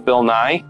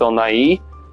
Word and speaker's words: Nighy: Bill Nighy, I used to think this guy's name was Bill Nye, but I guess it's pronounced Bill Nighy, which I'm still trying to make sure I pronounce it that way, is the funniest one of the characters in this film, playing Emma's neurotic father.